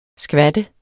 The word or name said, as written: skvat